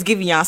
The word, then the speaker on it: Giveon: His name is Giveon. I told y'all his name is Giveon. So whoever was in my inbox telling me I keep saying his name wrong, I was not saying his name wrong.